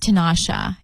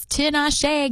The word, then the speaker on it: Tinashe: Britney Spears pronounced her name wrong. It's not Tinashe. It's Tinashe. Get it right.